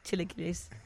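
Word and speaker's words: chilaquiles: Chilequiles. Esmeralda, am I still saying that incorrectly? Chilequiles. – No, you're saying that right.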